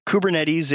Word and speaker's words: Kubernetes: So Kubernetes is the somewhat obvious pronunciation of the full spelling, but right. When you abbreviate it to just K-U-B-E, then the English word cube immediately comes to mind, and it's much more approachable to call it cube than kube.